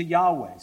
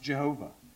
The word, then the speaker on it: Yahweh: And the way that works out in the Hebrew, it comes out roughly to Yahweh, sometimes pronounced Jehovah.